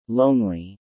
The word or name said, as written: lonely